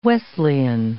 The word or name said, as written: Wesleyan